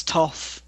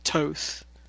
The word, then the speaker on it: Thoth: There are different pronunciations, so it may be that it's Toth in the US, but I've always said Toth.